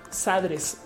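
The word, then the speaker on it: xadres: Entonces, y dicen, no, pues es que es como pues se pronunciaría como se dice xilófono, sadres.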